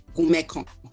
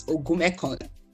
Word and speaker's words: Ogunmekan: How is it pronounced? Ogunmekon. Ogunmekon. – Ogunmekon. Are you not a Yoruba man? I'm the crossover Yoruba person. I'm actually from River State, so it's Ogunmekon.